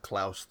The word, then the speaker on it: Klaus: It is pronounced Klaus, though.